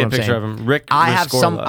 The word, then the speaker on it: Rescorla: And it's, I don't know how you pronounce it, but it's R-E-S-C-O-R-L-A. – Rescorla. – Rescorla. – Oh, Rescorla. – Yeah, there's an R. Rescorla.